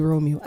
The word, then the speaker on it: Romeo: My social media is basically my name, but it's spelled with a B-B-I-E. So Debbie, D-E-B-B-I-E. – Yeah, Debbie Romeo. And yes, Romeo like Romeo and Juliet.